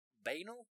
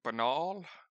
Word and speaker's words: banal: It's actually anal, but with a B in front of it. – It's B-anal.